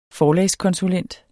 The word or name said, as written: forlagskonsulent